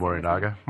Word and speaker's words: Morinaga: Morinaga? – Yeah, Morinaga chocolates with sodium cyanide.